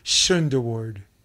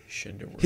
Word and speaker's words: Schunderward: He goes, Schunderward. And I go, Schunderward. He's like, Schunderward. – He goes, yep.